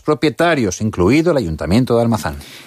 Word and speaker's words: Almazán: Sí, yo sí lo he pronunciado bien, Almazán.